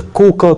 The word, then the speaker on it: Коука: Есть целых два музея на территории вот уезда Коука, который в популярных книжках обычно называют Кога, хотя местные жители не звончают этот слог, да, бьют себе пяткой в грудь, говорят, наше родное селение читается как Коука, да, но, видимо, по аналогии с Игайо, сами японцы стали читать так.